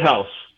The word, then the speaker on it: Haus: How do you pronounce beer Haas properly? Because I was accused of getting the Bavarian beer Haas incorrect. How do you say that? – H-A-U-S. – Beer house. – It's just a house, man.